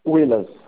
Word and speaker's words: Willas: Como que pronuncia seu nome? É Willas ou Willas? – Willas.